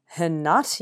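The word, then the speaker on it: Herrnhut: They're basically based in a village called, I think it's pronounced Hernut.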